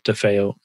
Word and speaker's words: DeFeo: Same thing, Copypulse or Matt DeFeo. My name's spelled weird. So D-E-F is in Frank, E-O.